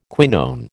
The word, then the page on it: quinone: American English